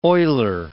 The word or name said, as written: oiler